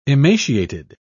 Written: emaciated

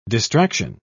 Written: distraction

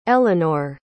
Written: elinor

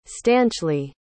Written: stanchly

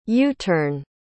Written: u-turn

